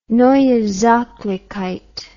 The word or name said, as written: Neue Sachlichkeit